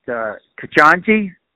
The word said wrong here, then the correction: Ketanji: Ketanji Brown Jackson, I think, is my right.